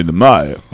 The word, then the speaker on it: Mnemonic: The M is silent. Okay. You know? I mean, you got to say it. It's Johnny Mnemonic.